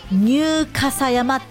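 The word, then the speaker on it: 入笠山: どんな漢字書くかというと、入るという字と、あの竹冠に立つと書いて傘、そして山、ニューカサ山って読むんです。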